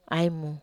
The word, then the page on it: ai mu: Wamesa